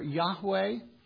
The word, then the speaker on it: Yahweh: By nearly all unanimous consent and based on the available evidence that we have, it is believed that the divine name was pronounced either Yahweh or Yahweh, depending on whether you took the W and pronounced it like a V.